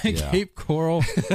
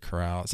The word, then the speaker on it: Cape Coral: Is it Cape Coral or Cape Corral? I think it's Cape Coral. – It's definitely Coral. – Cape Coral. born in Cape Coral, Florida. Yeah, I don't know why I said Corral.